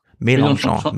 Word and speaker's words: Mélenchon: Man kämpft um Melanchons, nee, wie heißt er richtig? Mel Melanchon, nee, wie? wie Melanchon. – Mélenchon, Mélenchon, genau. – Mélenchon, so spricht man ihn aus.